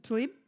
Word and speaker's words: Tlaib: Rashida Tlaib. Did I say it right? – Yes. – Tlaib? – Yeah.